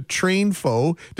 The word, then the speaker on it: TrainFo: I like how you've combined the word train and info to TrainFo. Did I say that correctly? – You said it exactly right, and you got it, the genesis behind the name exactly right as well.